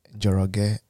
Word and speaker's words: Njoroge: If you just go to LinkedIn and search me up, Simon Njoroge, you'll see me. – Mm-hmm. Could you spell that for those who are not Kikuyu? – Okay, Simon, S-I-M-O-N, mm-hmm. and then last name, Njoroge, N-J-O-R-O-G-E.